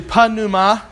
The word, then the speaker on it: pneuma: It's actually pneuma because they do pronounce the letter P. And it's like in pneumonia.